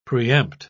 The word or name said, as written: preempt